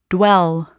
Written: dwell

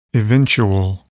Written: eventual

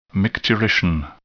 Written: micturition

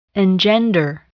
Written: engender

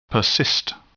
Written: persist

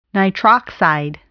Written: nitroxide

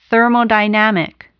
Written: thermodynamic

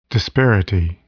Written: disparity

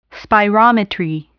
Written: spirometry

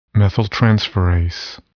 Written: methyltransferase

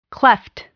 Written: cleft

